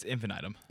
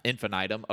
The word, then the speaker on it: Infinitum: It's Infinitum. – Infinitum. Okay. I appreciate that because I've been pronouncing it incorrectly.